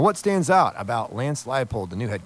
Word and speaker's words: Leipold: That's how it's pronounced. Leipold, not Leopold, or not Leopold, or, you know, Leonardo DiCaprio.